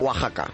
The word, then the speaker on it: Oaxaca: Oaxaca se pronuncia... Sí, Oaxaca. Nunca supe pronunciar eso. – No, es que los mexicanos la X es una J con los nombres de ahí. – Ah, perfecto. – Oaxaca.